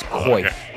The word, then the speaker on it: coif: It's pronounced coif in Canada, not quaff.